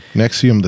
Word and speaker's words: NXIVM: It's spelled differently. It's okay. NXIVM. How cool is that? – Nexium, the sex cult.